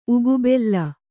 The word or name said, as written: ಉಗುಬೆಲ್ಲ